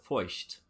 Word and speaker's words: Feucht: I'm going to mispronounce his name because his last name is a German name. – Uh-huh. – And his first name is Sean, and I pronounce it Feucht. – Feucht. – F-E-U-C-H-T.